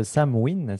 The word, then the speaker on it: Samhain: Alors, ça se prononce Samhain. Ça s'écrit Samhain, effectivement, Jean-François, mais ça se prononce Samhain. C'est du gaélique, l'écossais ancestral. Et effectivement, c'est il y a 2500 ans. En irlandais, on va dire Samhain.